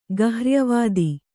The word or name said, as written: ಗಹ್ರ್ಯವಾದಿ